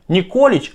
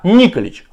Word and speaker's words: Николич: Оказывается, преемник Семина Марка не Николич, а Николич. Ударение ставится иначе.